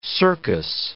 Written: circus

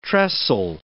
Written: tressel

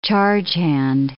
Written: chargehand

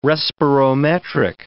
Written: respirometric

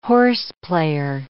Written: horseplayer

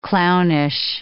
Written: clownish

